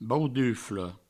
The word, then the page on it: baudufla: Provençal; bauduflo